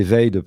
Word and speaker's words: Vade: Et ensuite, on a prononcé Veid parce que les Américains, il fallait se mettre d'accord entre la façon de prononcer et la façon de l'écrire aussi pour les Japonais qui écrivent phonétiquement.